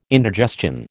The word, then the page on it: indigestion: American English